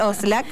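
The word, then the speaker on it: Oszlak: Oslak se escribe O-S-Z-L-A. Sí, Oscar Oslak.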